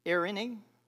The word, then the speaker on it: Eirene: Eirene, E-I-R-E-N-E.